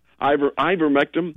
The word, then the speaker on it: ivermectin: She received the ivermectin. – I pronounce it wrong every time, too. – Yeah, ivermectin.